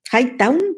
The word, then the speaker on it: Hightown: Star Play nos trae Highton, Hightown, perdona que lo he pronunciado mal, y Amazon Prime Video trae Motherland Fort Salem, la, segunda temporada.